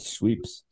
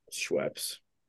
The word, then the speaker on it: Schweppes: Is that how you pronounce it? Schweppes? I always said Sweeps. – Sweepies. – Schweppes.